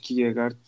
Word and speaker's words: Kierkegaard: Bir, daha önce varoluşçunun felsefenin kurucusu Kierkegaard, Kierkegaard diye de telaffuz ediliyor.